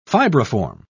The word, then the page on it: fibriform: British English